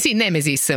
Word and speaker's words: Nemesis: Nemesis, ho detto bene adesso? – Giusto, sì. – Esatto, mi viene di mettere l'accento appunto nella seconda I.